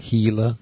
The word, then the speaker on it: Gila: Hey, you've heard of a Gila monster? – It landed on 4, I think. – Gila. It's spelled with a G.